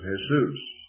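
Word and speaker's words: Jesús: They pronounce it Jesus. The J is an H.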